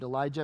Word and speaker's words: Elijah: In fact, I have a cousin named Elijah, and we call him Lige for short.